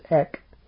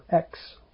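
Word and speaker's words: ek (ἐκ): It's, I think in the Greek it's pronounced ek. Ek or X. Two different pronunciations, but either will do.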